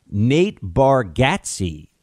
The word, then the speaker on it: Nate Bargatze: Nate Bargatze is actually how you say his name.